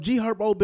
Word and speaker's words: G Herbo: G Herbo been around for a minute.